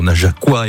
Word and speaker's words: Najaquois: Depuis 20h, on s'est pas mal baladé, même si on a offert un joli coup de projecteur aux Najaquois et aux Najaquoises. Bien comme ça qu'on prononce. – Tout à fait.